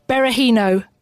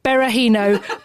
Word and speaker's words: Berahino: It had the player himself looking straight into the camera, just saying Berahino. Berahino. My name's Berahino. – Because I think he got a bit upset with everyone mispronouncing it left, right, and centre.